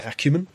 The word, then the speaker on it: acumen: But, acuity. – Yeah. Oh. – Acumen? – Yeah, thank you. That's the word I'm looking for.